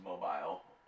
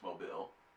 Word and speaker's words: Mobile: It's not pronounced mobile, it's Mobile. But it's crazy because every year they do what's called a moon pie drop, like on like New Year's.